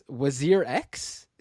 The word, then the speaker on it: WazirX: So earlier this month, an Indian cryptocurrency exchange called, I don't know how to pronounce this, WazirX? Is that how you pronounce it? – Sounds right. – All right, I'll go.